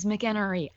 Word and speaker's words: McEnery: I feel like you write into a couple podcasts that I listen to, and I feel like people have had issues perhaps pronouncing your last name correctly. So my last name's McEnery. I understand. It's very easy to have a mistake said about your last name. – I'm Darren, D-A-E-R-O-N.